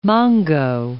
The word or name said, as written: mongo